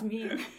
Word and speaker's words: meme: I always wanted to mispronounce it because I have a French background, so I always wanted to say meme, but it's not meme, it's meme.